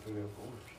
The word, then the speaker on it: Pooneil Corners: obviously Pooneil Corners. Did I say it wrong? Please tell me I didn't say it wrong. – No, you did it right, brother.